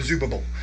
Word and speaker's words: Zerubbabel: Saying the name Zerubbabel over and over is actually really fun, because to say his name, you have to go b-b in the middle, b-b-b, Zerubbabel.